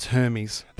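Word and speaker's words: Hermès: Is it Hermes? – Hermes. – No, Hermes. – Hermes? – No, it's Hermes. – It's a silent H.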